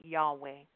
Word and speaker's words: Yahweh: It's just a Hebrew name, and it's spelled a capital YHWH, and they pronounce it Yahweh.